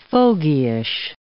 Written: fogyish

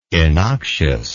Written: innoxious

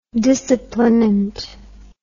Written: disciplinant

